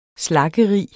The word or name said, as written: slaggerig